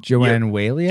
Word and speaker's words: Joanne Whaley: Joanne Whaley? – Joanne Whaley is lovely to see.